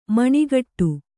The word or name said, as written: ಮನಗೂಡು